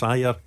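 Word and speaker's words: Ajer: Hold on, just before we go any further, it's taken until he's left for you to learn how to pronounce his name properly. Is that what you're telling me? – Adger's away to Brentford. – For the last 4 years he's been Adger. Now that he's gone, you're giving him his correct pronunciation.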